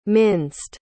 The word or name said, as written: minced